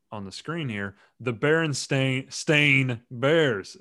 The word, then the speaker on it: Berenstain: It's not Berenstain spelled like Berenstein or Berenstain. It's Berenstain. It's spelled the Stein, Stein, Stain part is S-T-A-I-N.